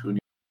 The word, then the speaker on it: Huni: It's it's pronounced Huni. Huni. Huni.